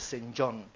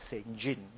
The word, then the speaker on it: St. John: Some pronounce it as St. John, but most will pronounce it as St. John when it comes to people's name.